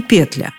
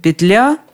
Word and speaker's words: петля: И петля, и петля. А вот более строгий, словарь ударения Агеенко и Зарвы, выбора не оставляет. Петля и только петля. Петля, петли, петле, петлёя, а петле, как видно в единственном числе ударение на последнем слоге.